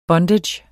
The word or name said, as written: bondage